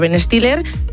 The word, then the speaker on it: Ben Stiller: La película pues es protagonizada por Ben Stiller. Sí, lo he dicho bien. – Ben Stiller, ¿no? – Perfecto.